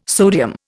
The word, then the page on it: sodium: Indian